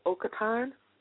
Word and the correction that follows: Otakon: 13? Or is it Oktan? – It's Otakon.